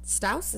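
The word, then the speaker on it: Stause: Stouse? Is that how you pronounce it? – Yes.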